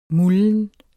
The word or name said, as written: mulden